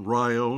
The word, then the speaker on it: Rio: And all through Illinois, we mispronounce names. Milan instead of Milan, Rio instead of Rio. – Right. – We accent the first syllable.